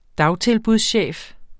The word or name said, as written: dagtilbudschef